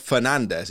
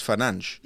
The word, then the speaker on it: Fernandes: It's not Fernandez, it's Fanange.